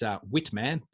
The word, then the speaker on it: Witman: It is called Witman. That is correct pronunciation. It's Witman, but it is clearly the German influence here.